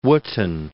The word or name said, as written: Wotton